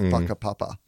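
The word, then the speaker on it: whakapapa: But the podcast is very much just about life and life philosophy and this idea of this Maori concept called, well, it's spelt Waka Papa, W-H, but it's actually pronounced like in a way that I certainly don't want to make fun of because, you know, cultural sensitivities, but it's pronounced Waka Papa.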